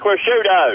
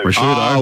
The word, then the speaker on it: Ricciuto: You duffer, it's Mark Ricciuto. – Ricciuto.